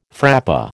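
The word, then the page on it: frappé: American English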